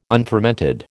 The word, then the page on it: unfermented: American English